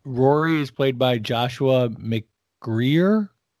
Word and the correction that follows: McGuire: I think it's McGuire.